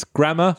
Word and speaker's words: Gramma: And you spell it G-R-A-M-M-A, right? – Yes. I left that spelling up to Phil, and I just said it's grammar, like grammar as in spelling.